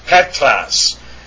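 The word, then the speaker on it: Petros: And that is how you pronounce that, Petras. And you notice that the accent is over the epsilon, Petras.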